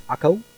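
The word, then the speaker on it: Accho: Neither did Asher drive out the inhabitants of Akcho, how do you want to pronounce that? Akko, nor the inhabitants of Zidon, nor of Alab, nor of Akzib, nor of Helba, nor of Aphek, nor of Rehob.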